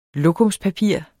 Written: lokumspapir

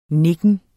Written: nikken

